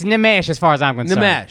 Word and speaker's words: Nimesh: I flub on Indian names because you guys put the emphasis on different syllables. – Yeah. – time. – Yeah. – Like Nimesh. – He's a fucking idiot. Nimesh Patel is my guy, but his name is Nimesh, all right? – Nimesh?